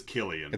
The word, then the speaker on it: Cillian: It is Killian. – It's a hard C? – Like a hard K. I just looked it up phonetically on Wikipedia. – Okay. Killian Murphy, like Killian's Red. – Killian. That's right. He's an Irish actor, so that's why it's Killian.